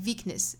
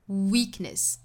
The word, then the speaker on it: weakness: 尤其像 weakness 这个词，很多同学可能会读成 weak, 可能会和上齿去碰触下唇的那个 v 的音混淆了哈，会读成 weakness, 应该注意是嘴巴嘟起来的那个半元音辅音的 w, w, weakness,